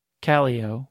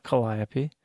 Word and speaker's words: Calliope: We mispronounce nearly all of them, starting with Callio, which is not Calliope.